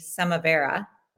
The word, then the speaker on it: Samavira: And so working through your own traumas and finding meditation, you then decided to start your company, Samavera, right? – Correct. Well pronounced.